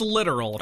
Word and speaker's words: littoral: Littoral? I wanted to say literal. – But it's, yeah. Well, it's pronounced literal.